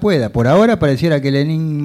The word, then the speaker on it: Lenín: Vos fijate que hasta Lenín Moreno, Lenín porque él lleva acento en el caso de hasta Lenín Moreno, está rompiendo con Correa y planteando retrocesos en algunas cuestiones, ¿no?